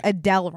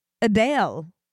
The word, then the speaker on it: Adele: We've been pronouncing Adele wrong. – Can I guess what the actual pronunciation is? – Yeah, please. – Adel? – No, it's Adele.